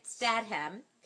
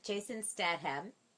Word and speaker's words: Statham: It stars Jason, I'm going to get his name right, Statham. I did it, Jason Statham.